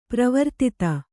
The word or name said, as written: ಪರಿವರ್ಧಿತ